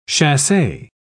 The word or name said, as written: chasse